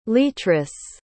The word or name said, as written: leatrice